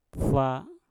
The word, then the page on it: fa: Mpur